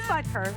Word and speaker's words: Butker: Can you read it is Butker.